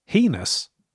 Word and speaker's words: heinous: I've been listening to the episode about slippers, and I noticed that Ben said the word heinous, but pronounced it like penis. – Mm. – I did a poll on my Instagram a while ago about this, and the consensus was that the word heinous is actually pronounced like anus, a.k.a.